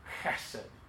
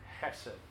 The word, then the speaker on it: Hesed: If you know anything about the Hebrew language, you got to put a kind of CH sound. Sometimes it's spelled that way with a CH or a K. Hesed. Hesed.